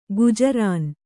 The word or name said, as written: ಗುಜರಾನು